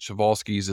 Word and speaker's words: Przewalski: Some say Przewalski's, but Przewalski's is the proper Polish pronunciation.